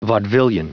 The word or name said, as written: vaudevillian